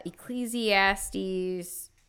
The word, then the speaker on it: Ecclesiastes: Ecclesiastes 3 verses 1 through 8. I don't even know if I said that right, y'all. – Sounded it's correct.